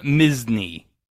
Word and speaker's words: Misny: Starting with Tim Misny. Like Disney, but it's spelled M-I-S-N-Y.